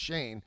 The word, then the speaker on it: Schoen: So the second round in person, and I believe the guy from Buffalo, Shane, it's spelled S-C-H-O-E-N, but it's pronounced Shane, he was being interviewed as recently as like 1 o'clock, so he might still be in there.